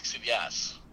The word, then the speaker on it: Xidias: So the last name is Xidias.